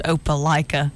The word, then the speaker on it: Opelika: And so I was like, Opelika, what is that? Mm. My mom and them fell out and said, Mom, I think it's pronounced Opelika. Mm. Oh, well, it's spelled like Opelika.